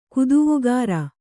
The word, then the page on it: ಕುದುವುಗಾರ: Kannada